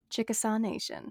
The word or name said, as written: Chickasaw